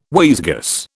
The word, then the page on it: wayzgoose: British English